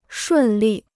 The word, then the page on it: 顺利: shùn lì